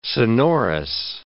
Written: sonorous

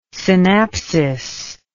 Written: synapsis

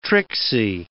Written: tricksy

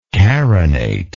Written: carination

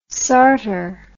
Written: sartor

